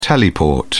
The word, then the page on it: teleport: British English